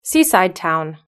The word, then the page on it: seaside town: British English